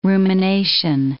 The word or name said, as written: rumination